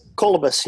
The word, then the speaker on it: Kalibas: Kalibas, yeah. – Oh, Kalibas. Yeah, I had the wrong emphasis on the wrong syllable.